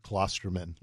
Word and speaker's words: Klosterman: And yes, it is not Klosterman, like everybody else pronounces it.